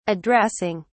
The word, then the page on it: addressing: British English